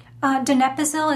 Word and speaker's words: donepezil: That is also a common thing with people with Alzheimer's and various dementias that take, some folks know it as, how do you say that, donepezil? – Donepezil is the generic name. – Donepezil, okay.